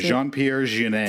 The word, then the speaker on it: Jean-Pierre Jeunet: but I feel like the director for Amelie, which I wrote his name down, and I can pronounce Jean-Pierre Jeunet. – Thank you. Cause I was going to sound very offensive if I tried to, I'm like, again, from Ohio, Jean-Pierre Jeunet.